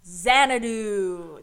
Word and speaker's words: Xanadu: That is spelled X-A-N-A-D-U. Xanadu. – That confused me because I thought it was Z. I was like, hold on, you're doing X in the alphabet, but you just said a Z word. – Nope. – But then I realized, oh wait, X can be pronounced like Z as well. – Yes, like Xavier.